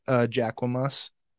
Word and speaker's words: Jacquemus: Jacques Mousse. – It's pronounced Jacquemus.